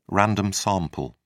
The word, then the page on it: random sample: British English